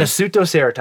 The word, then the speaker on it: Nasutoceratops: But Allosaurus and then another one called the Nasutoceratops. I got it right. – Did you really? – Nasutoceratops. – I kind of want to see it to fact check here. – It looks like it's spelled wrong, but I promise you it's not. It's a herbivore triceratops looking motherfucker, probably. I don't know. – I'll be damned. But, I think you said it right.